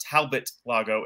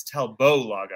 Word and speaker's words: Talbot Lago: And the man came up and said, no, it's not Talbot Lago. It's Talbot Lago.